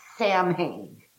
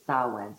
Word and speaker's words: Samhain: It's spelled as Americans. We would say Samhain, right? Mm-hmm. And the correct Gaelic pronunciation is Samhain. So I always think of that M as being upside down and that H being silent, you know.